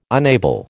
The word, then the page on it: unable: American English